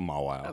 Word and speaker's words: Mawile: Mawile.